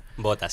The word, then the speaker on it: Botha: Contadnos un poco en qué consistió la estrategia total que ideó el presidente Pic Botha. No sé si se pronuncia así. – Botha, sí.